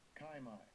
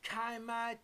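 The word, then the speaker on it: Chi-Mai (κεῖμαι): This is rooted in another word that, let's hear the pronunciation. – Strong's G2749, Chi-Mai. Chi-Mai, to lie, meaning lie down, infant, buried.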